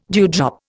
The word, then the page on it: dewdrop: Indian